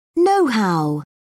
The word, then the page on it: nohow: British English